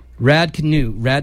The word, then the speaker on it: Raducanu: Britain's very own Emma, I can never say her last name, Raducanu.